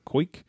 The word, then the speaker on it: Koike: Crabtree wrote that court documents say that Cage lacked understanding of his actions in marrying, I'm sure I'm saying this wrong, but Koike or Koike? – You nailed it. – K-O-I-K-E.